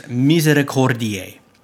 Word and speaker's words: Misericordiae: The correct word is Misericordiae, Misericordiae. I was saying Misericordiae, which is, that's not even how you pronounce the word.